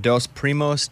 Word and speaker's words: Dos Primos: So it's called Dos Primos Tequila.